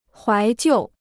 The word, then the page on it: 怀旧: huái jiù